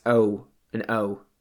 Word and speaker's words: Oa: Uh, it's just literally the letter O and A. Oa, uh, yeah, yeah, that's it. And apparently it's pronounced O and O.